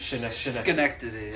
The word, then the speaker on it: Schenectady: But Jim Morrison lives in Schenectady, New York. Is that how you say it? – Schenectady. – Schenectady.